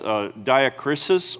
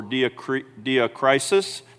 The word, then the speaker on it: diakrisis: It's either pronounced diakrisis or diakrisis. Doesn't matter. It's D-I-A-K-R-I-S-I-S. That doesn't matter either, I guess. But,